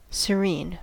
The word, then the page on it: serene: American English